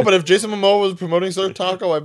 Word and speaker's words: Jason Momoa: D- is it me or does Jason Momoa? Uh, did I say the name right? – Yeah, yeah.